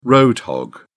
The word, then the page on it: road hog: British English